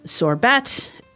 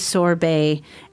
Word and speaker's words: sorbet: So just remember the pronunciation is not sorbet, it is sorbet.